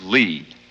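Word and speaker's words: -ly: This is Lee. It's spelled L-Y, and it's very useful.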